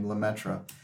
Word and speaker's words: Lemaître: Georges Lemaître, who in an anglicized way of pronouncing his last name, Lemaître,